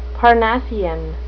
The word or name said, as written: parnassian